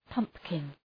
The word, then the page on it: pumpkin: {'pʌmpkın}